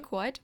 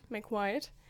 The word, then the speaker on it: McWhite: Wie spricht man das? McWhite. – McWhite. Den sie... – McWhite, ja.